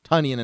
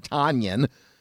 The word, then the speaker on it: Tonyan: And then sometimes they'll get Robert Tonyan and they'll still mispronounce his name, even though we've been pronouncing it Tonyan and not Tonyan for a couple of months now.